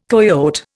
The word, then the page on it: coyote: Indian